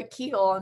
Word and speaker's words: Kuechle: Kuechle, almost. Yeah, halfway there. – Almost. I think of a Kuechle, and for some reason, Nicole, when I think of you.